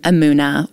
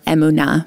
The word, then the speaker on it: emuna: Yes, the next word we're going to talk about, we mentioned already, it's the word emuna, or emuna, more properly pronounced in Hebrew.